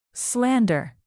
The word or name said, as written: slander